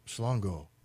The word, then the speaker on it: Mhlongo: We've been told it's pronounced Shlongo, even though it's spelled M-H-L-O-N-G-O.